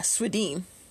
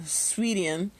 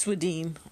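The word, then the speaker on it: Swedien: I had to look up Bruce Swedine. That's how you pronounce it. All this time. Yeah, Swedine. Oh, it even says so right there. Pronounced Swedine. For some reason, every time I looked at it, I thought it was pronounced Sweden. But it's Swedeen. Okay, so.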